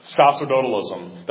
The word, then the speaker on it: sacerdotalism: Sacerdotalism. That's spelled S-A-C-E-R and then dotalism. D-O-T-A-L-I-S-M. Sacerdotalism.